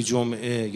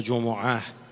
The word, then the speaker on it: جمعه: نکته اول این که فرمود در آیه دوی سوره جمعه یا جمعه، هر دو تلفظ درسته، هو الذی بعث فی الامیین رسولا منهم.